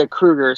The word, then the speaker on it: Kruger: Is that, you pronounce it Kruger? Kruger? – Yeah, Kruger.